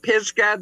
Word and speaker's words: Pisgah: But because it looks like there's so many great stops along the way, like the, how do you say it's Mount Pisgah? – Mount Pisgah, that's up near Cripple Creek.